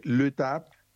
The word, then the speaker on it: l'étape: Sí, en España, bueno, se escribe l'étape, pero en realidad es l'étape, ¿no? No se pronuncia la S, l'étape, es la etapa, by Tour de France.